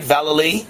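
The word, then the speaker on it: Vallely: Now, am I pronouncing it right, Valley? – That's right.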